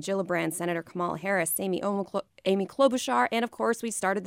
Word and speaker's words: Klobuchar: Klobuchar. – Yes. – Klobuchar.